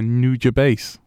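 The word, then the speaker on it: Nujabes: In my head, it sounds like new jazz bass.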